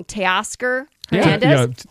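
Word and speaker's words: Teoscar Hernández: So two players, I might say his name wrong, Teoscar Hernandez. – Yeah. – Is that right? – Yeah. Well done.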